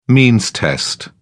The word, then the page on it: means test: British English